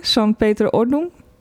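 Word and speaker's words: Sankt Peter-Ording: Kun jij dat beter uitspreken? Sankt Pieter Ordnung? – Volgens mij spreek je het goed uit.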